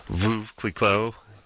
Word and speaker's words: Veuve Clicquot: Veuve Clicquot. Did I pronounce that right? – Yeah. Sure. – Veuve Clicquot.